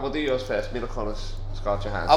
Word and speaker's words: Kunis: Mila Kunis.